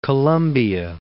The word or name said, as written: District of Columbia